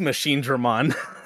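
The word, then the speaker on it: Machinedramon: I'm probably missing like one or two syllables, but it's Machine Dramon, right? – It is Machine Dramon.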